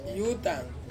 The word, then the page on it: utang: Ansus